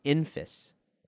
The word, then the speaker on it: Enfys: is actually pronounced Enfys, kind of like emphasis. So it's Enfys Nest, not Enfys Nest.